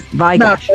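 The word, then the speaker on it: Vai/Gash: Is there supposed to be a pause? Like, what's the right way to say this? Vai Gash. – No, yeah, that's it.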